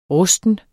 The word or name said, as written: rusten